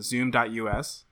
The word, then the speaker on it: Zoom.us: Zoom.us? – Yes, Zoom.us.